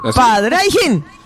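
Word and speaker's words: Pádraigín: Padraigín, pero tiene acento en la primera A y en la última I. ¿Cómo se dice eso? – Acentúa las dos. – Padraigín.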